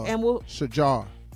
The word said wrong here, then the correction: Sharjah: Sharjah. Shajar. Sharjah. Sharjah. Yeah, I mispronounced Sharjah.